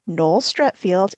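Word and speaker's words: Noel Streatfeild: The castaway was Noel Stretfield, and that's exactly how Roy pronounces her name. And she did not object or correct his pronunciation.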